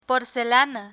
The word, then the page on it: porcelana: Spanish